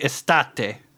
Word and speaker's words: estate: And, can you rhyme that for me with something? – Estate. Fuck. Fuck. – It rhymes with latte.